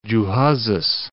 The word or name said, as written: Gjuhezes, Cape